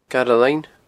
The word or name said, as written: Garbh Linn